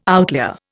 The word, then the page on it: outlier: Indian